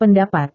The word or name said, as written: pendapat